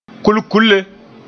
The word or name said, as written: kulkulle